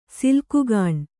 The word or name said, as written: ಸಿಲ್ಕುಗಾಣ್